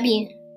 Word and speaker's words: bien: It's spelled a little bit like bean, not really. Bien, B I E N.